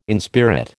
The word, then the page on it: inspirit: American English